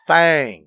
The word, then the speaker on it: Thang: No, Thang. – Yeah, T-H-A-N-G. That's exactly how it's written. – Alabama White Thang. – And that's how it's written. That's how it's pronounced.